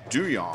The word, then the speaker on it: Duhon: So Andrew Duhon's name is actually pronounced Doo Yong.